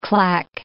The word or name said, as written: clack